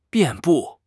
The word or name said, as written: 遍布